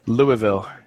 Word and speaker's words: Louisville: I think he needs to say Louisville correctly. – Yeah. We discussed that. Louisville. Is that correct? – Close enough.